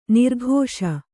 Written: ನೊರಕಿಸು

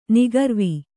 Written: ನಿಖರ್ವ

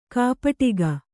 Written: ಕೈಪೆಟ್ಟಿಗೆ

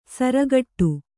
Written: ಸಾರಗೆಡು

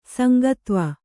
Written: ಸಂಗತ್ವ

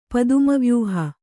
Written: ಪದ್ಮವ್ಯೂಹ

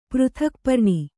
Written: ಪೃಥಕ್ಪರ್ಣಿ

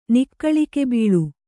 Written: ನಿಕ್ಕಳಿಕೆಬೀಳು

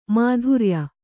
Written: ಮೈತ್ರೇಯ

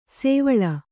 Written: ಸೋವಳಿ

